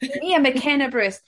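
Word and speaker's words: Mia McKenna-Bruce: Because I know you've got Mia McKinley Bruce. Have I said her name wrong? McKenna Bruce. – There we go. – Mia McKenna Bruce.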